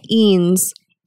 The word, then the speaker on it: Eanes: We say Eanes. – Eanes. – Like beans without the B.